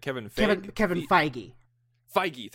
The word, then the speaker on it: Kevin Feige: Kevin Feige, thank you.